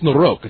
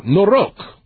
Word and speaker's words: Noroc: I thought it was Noroc, but I think it's Noroc. It's a long O. Noroc.